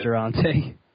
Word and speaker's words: Durante: I've always pronounced his name in my head Durante. – Durante? That's actually what I was thinking. – Yeah, it is Durante. – It is it? Oh, I just pronounced it Durant.